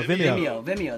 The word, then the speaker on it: Vimeo: It's, you know, the YouTubes, VMO, or I don't know how you say it, but anyway. – Oh, Vimeo. – Vimeo, that's right. Yeah. – Yeah, I guess like video with an M instead of the D, yeah, or whatever.